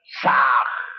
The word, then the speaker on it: Sah: Han se escribe con las letras H-A-M. Sa se escribe con las letras S-A-H. La H suena siempre como J.